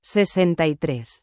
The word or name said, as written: 63